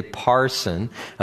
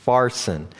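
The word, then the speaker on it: pharsin (פַּרְסִין): Now, I know that some of your translations say parson, but the actual word is, it's pronounced with a PH sound. It's pharsin.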